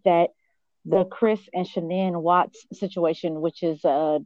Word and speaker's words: Shanann: So, you had said that am I pronouncing her name right, Shanann? – Yeah, Shanann.